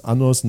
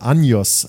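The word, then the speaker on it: Años: Im Original habe ich natürlich als nicht spanisch sprechender Mensch in unserem Original-Podcast auch noch falsch ausgesprochen, habe gesagt, im Original heißt die Los Anos Nuevos, die heißt, ja, da sagt man aber Anjos, weil da so ein Kringel über dem N ist.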